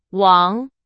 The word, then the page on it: 王: Chinese